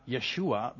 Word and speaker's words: Yeshua: Yeshua. Maar Yeshua betekent, ja, dat is de Hebreeuwse uitspraak van Jezus.